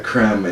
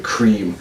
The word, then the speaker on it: crème: It's like the first time I ordered a Café Avec Crème in Paris, and I pronounced it cream, to which the waiter oozed back, crème.